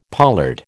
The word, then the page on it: pollard: American English